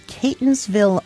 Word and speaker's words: Catonsville: Elizabeth is calling from Catonsville, Maryland, and did I pronounce that correctly, Elizabeth? – Yes, you did.